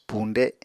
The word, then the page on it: puñde: apatani